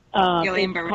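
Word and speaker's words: Guillain-Barré: And because of the chemicals that they were putting in it, it caused another disease, the Guillain-Barre. I know I pronounced that wrong. Guillain-Barre or whatever. – Guillain-Barre.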